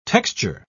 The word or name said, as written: texture